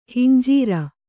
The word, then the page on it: ಹಂಜರ: hanjara; kannada